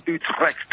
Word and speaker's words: Utrecht: And I think we start out of the Dutch city of Utrecht, if I pronounce it correctly. – Yeah, well, you can say Utrecht, but I've been taught by the locals that it's Utrecht.